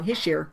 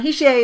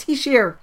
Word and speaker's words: Hischier: You know, like Hischier. Oh my God, I said it wrong. Hischier. Yeah, it's not Hischier. It's Hischier. He's Swiss.